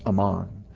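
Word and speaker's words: Amman: It's pronounced Ammon.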